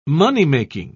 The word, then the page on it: moneymaking: British English